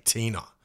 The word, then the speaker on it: teina: The Maori word, and it's phonetically spelt T-E-I-N-A, Tina.